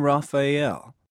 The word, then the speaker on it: Rafael: I'm Raphael. – How do you spell that? – R-A-F-A-E-L.